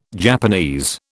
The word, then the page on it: Japanese: British English